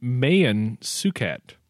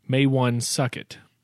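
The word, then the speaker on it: Maewyn Succat: He was actually named Mayan Suket, not Maywan Suket, which some people pronounce it. – Are you serious? – I mean, it's spelled with M-A-E-W-Y-N, and then the last name is S-U-C-C-A-T.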